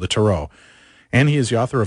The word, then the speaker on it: tarot: but generally in dictionaries it says tarot. – All right, tarot. – But you could go tarot. It doesn't matter as long as you don't pronounce the final T.